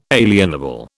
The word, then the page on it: alienable: British English